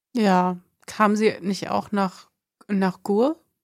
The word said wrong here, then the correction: Gurs: Nach Gurs, ja, genau. Gurs. – Oh, ich habe es falsch ausgesprochen.